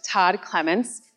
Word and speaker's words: Todd Clements: But here to talk more in depth of the hauntings on Mackinac is my good friend, Todd Clements, paranormal author and owner of Haunts of Mackinac Tours. So come on out, Todd. – Hello. – Did I get it all right? – You pronounced it correctly.